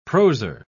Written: proser

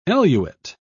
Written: eluate

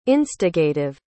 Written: instigative

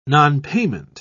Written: nonpayment

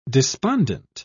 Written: despondent

